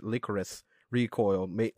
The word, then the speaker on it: Lycoris Recoil: I mean, I pronounce it Lycoris Recoil, mainly because I feel like it kind of looks and sounds like licorice, but that just might be a weird thing on my part.